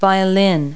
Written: violin

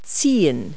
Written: tow

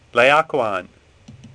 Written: Laocoon

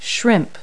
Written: shrimp

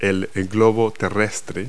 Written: globe

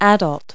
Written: adult

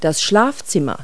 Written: bedroom

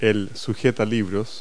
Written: bookend

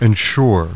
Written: ensure

